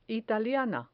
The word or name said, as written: italiana